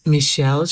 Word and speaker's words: Michele: Well, my name is Michelle Shea, M-I-C-H-E-L-E.